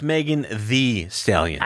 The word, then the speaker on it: Megan Thee Stallion: I believe it's pronounced Megan Thee Stallion. – I have to say it like that. I feel like an asshole when I say that, but you're supposed to say it like that, right? – Thee Stallion, yes.